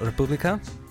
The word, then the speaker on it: Republika: So, it's a band called Republika, I think, is mm-hmm. how you pronounce it. Yeah? – Yeah.